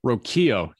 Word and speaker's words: Rocchio: And am I saying that right before you start correcting me? Rocchio? – Yes. Yeah. – Not Rocchio. Rocchio. – Rocchio. Yeah.